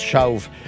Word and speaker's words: Shauf: Ça se prononce Schauf. S-H-A-U-F, OK, puis Andy comme de Andy.